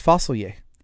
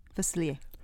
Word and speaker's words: Facilier: It's Facilier. – Facilier. – Watch the movie again. See how he pronounces it himself.